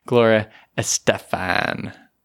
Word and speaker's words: Estefan: She's like, no, it's Gloria Estefan.